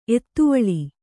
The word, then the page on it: ಎತ್ತುವಳಿ: ettuvaḷi